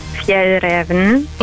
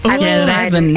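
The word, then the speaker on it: Fjällräven: Ich bin von der schwedischen Outdoor-Marke und es wird richtig ausgesprochen Fjell Raven. – Fjell Raven.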